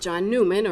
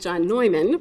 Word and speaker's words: Neumann: In footnote number 9, the document referenced a 1921 decree issued by the Holy See on the virtues of John Newman, or as he would have pronounced it, John Neumann, but in the United States pronunciation was Newman, a Bohemian-born missionary who had served as Bishop of Philadelphia from 1852 to 1860.